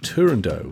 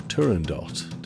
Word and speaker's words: Turandot: Number 17, featuring the aria Nessun Dorma, the opera by Puccini that was completed posthumously by Franco Alfano, is Turandot, or Turandot, depending on how you'd like to pronounce that one.